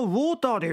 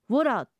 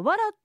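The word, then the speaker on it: water: 水はウォーターでよかやろ、なんで通じんかったぞ。 – ウォーターは日本語発音なんですよ。英語やったらウォラとかワラって言わないとですね。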